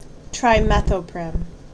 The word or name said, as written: Trimethoprim